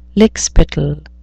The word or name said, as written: lickspittle